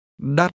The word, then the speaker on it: đắt: Đắt. Đắt. – Did you pronounce this word with a rising tone?